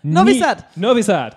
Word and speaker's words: Novi Sad: Novi Sad.